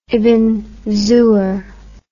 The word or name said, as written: ibn zohr